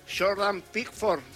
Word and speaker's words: Pickford: Jordan Pickford será el arquero.